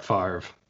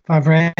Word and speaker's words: Favre: People that think he should pronounce his name how it's actually spelled. – Well, back in the day, before he was Brett Favre, he was Brett Favre. And then he one day dropped the Ray.